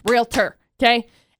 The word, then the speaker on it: realtor: No, realtor, realtor, realtor. Two syllables. Realtor. Okay?